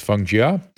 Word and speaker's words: Feng Chia: Former professor at a Taiwan University, I believe it's pronounced Feng Jia.